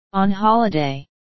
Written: on holiday

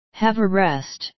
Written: have a rest

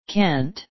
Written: can't

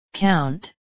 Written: count

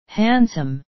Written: handsome

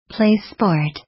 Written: play sport

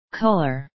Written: colour

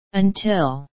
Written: until

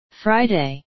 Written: friday

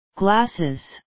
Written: glasses

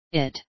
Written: it

